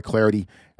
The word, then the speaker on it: Clardy: Give me the follow at Troy Clarity. The last name is spelled C-L-A-R-D-Y.